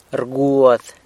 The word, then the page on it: rguad: sanlucasquiavini